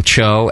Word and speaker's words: Tcho: It's spelt weird. But it's pronounced Cho, T-C-H-O.